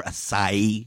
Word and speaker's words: acai: You know, we've all seen a mouth-watering healthy smoothie or acai bowl. I can never say that word right. – You said it. I think you said it right.